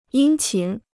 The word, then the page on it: 殷勤: yīn qín